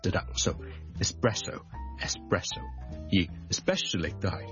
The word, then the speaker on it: espresso: 即特別地咧，尤其是咧，especially 第二個字咧，都係 es 開頭嘅就係 espresso， espresso， espresso， espresso，即係意式嘅特濃咖啡。我哋呢兩個字嘅 es 咧，我哋都唔好將佢讀成 x，唔好 espresso，唔好 especially， but espresso， e 或者 e 都得。直接加个 s，中间唔好加咗个 k 音，